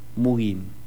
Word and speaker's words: mugging: G-G-I-N-G, Mugin.